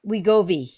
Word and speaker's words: Wegovy: What the other one is Gobi, you said. Was that it? Gobi? – Wegovy, yeah. – Wegovy.